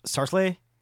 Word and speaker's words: seriously: That's pronounced Sarsley. – Sarsley. – Sarsley. Sarsley. People.